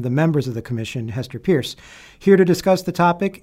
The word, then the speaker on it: Hester Peirce: I would just quickly clarify that it is Hester Pierce is how you pronounce the name.